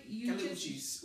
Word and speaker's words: Uchis: Yes, people, everyone should go listen to Kali Uchis. Am I saying it right? – Uchis.